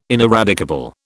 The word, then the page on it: ineradicable: British English